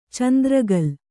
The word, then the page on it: ಚಂದ್ರಕೋಲು: candra kōlu